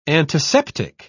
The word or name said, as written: antiseptic